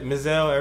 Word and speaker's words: Mizell: That's how you pronounce that? Mizell? Everybody's going with Mizell? – Yeah.